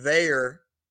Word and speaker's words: Veilleux: Christian Veyer. – That's right. – Pronunciation. Remember Conveyor Belt, Pitt fans, if that's the way that you want to go about it.